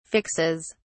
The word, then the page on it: fixes: British English